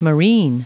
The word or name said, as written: marine